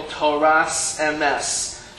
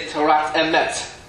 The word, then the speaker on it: Torat Emet: In Ashkenaz, they will pronounce this as sa, right? Like, for example, Toras Emes. However, in Sephardim, they would say Torat Emet. Right? But either way, they're both acceptable pronunciations.